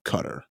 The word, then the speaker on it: Qatar: Qatar is how you pronounce it. That's what Fareed Zakaria says.